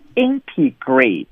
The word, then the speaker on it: integrate: 我们给大家提示啊，它的发音是 integrate，这个 ATE 是不是发成了 eight 啊？